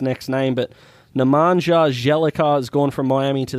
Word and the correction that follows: Bjelica: Nemanja Bjelica? – Sure.